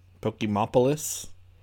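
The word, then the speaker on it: Pokémopolis: And these are To Master the Unexpected and The Ancient Puzzle of Pokeopolis. Did I say that right? – Pokemopolis. – Okay.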